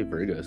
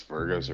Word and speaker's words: Virgo: Why do you say Virgo so weird? – Because Virgos are weird. You're weird. – You say it like an E instead of an I.